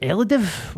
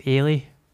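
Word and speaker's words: Eilidh: Her name's Ailey. Just at a barber's in the West End, but it's spelt like E-I-L-I-D-A-H-A. And I was like, Elidiv? What the fuck's Elidiv? So she got in touch and she was like, it's Ailey, you prick.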